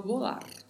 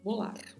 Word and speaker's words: volar: Bastante atenção à pronúncia, porque a letra V tem um leve som de P e o R tem um vibrato. Então vamos falar volar, volar.